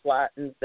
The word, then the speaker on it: Slatten: Slattens that, and I pronounced your name improperly, and so I apologize.